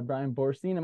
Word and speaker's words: Bryan Boorstein: So we are joined by Bryan Boorstein.